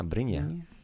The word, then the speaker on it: Brinje: Yeah, Brinje.